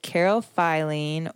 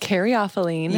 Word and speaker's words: Caryophyllene: and carophylline how you say it? Caryophyllene.